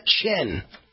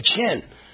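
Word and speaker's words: Tchen: Reports emerged last week Cook County State's Attorney Kim Fox had been in contact with Mrs. Obama's former chief of staff, Tina Chen, T-C-H-E-N, I think it's pronounced Chen, but who knows, who emailed her expressing the concerns that the Smollett family had about the ongoing investigation.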